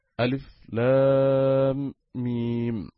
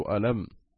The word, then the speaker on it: الم: ولذلك إنما يقول القارئ ألف لام ميم مثلا، ولا يقول ألم،